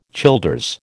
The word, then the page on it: Childers: American English